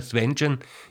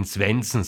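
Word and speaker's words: Svendsen: Edward Grieg hatte einen Kollegen, Landsmann und guten Freund, Johann Svenschen, ein berühmter Komponist, wenn ich das jetzt so ausgesprochen habe, Svenschen, ist das kein Sprachfehler, sondern so sagt man das angeblich auf Norwegisch. Aber Sie können auch weiterhin Svensen sagen, wie das meistens bei uns getan wird.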